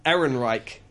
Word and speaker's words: Ehrenreich: Aiden Ehrenreich.